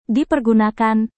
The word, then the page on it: dipergunakan: indonesian